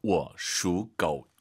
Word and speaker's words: 我属狗: 삼성이 세 개 앞에 반삼성입니다. 我属狗.